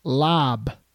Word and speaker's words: larb: But we had chicken larb, L-A-R-B, but it's pronounced lab.